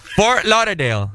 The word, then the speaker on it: Fort Lauderdale: Fort Lauderdale.